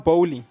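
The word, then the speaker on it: Boehly: So Chelsea has a new owner by the name of Todd how do you say his surname? – Oof. – Todd Bowley.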